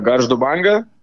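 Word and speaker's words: Banga Gargždai: Banga Gargsai, er det så? – Uh, yes, riktig. jeg spilte for uh, eh, Garzdubange.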